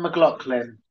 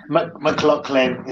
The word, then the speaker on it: McLaughlin: Sean McLaughlin. – McLaughlin. Okay. Yeah. I wasn't sure it was McLaughlin or... – McLaughlin. It's spelled Laughlin, but yeah, McLaughlin.